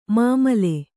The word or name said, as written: ಮಮ್ಮಲ